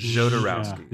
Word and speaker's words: Jodorowsky: So I've always done Jodorowsky. – He himself pronounces it Zh with a breath SH. So it's Jodorowsky.